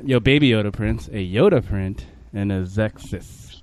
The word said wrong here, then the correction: Skeksis: Is that how you pronounce Skeksis. – It? Skeksis.